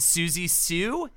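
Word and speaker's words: Siouxsie Sioux: And Susie Sue. Is that how you say it? – Yeah.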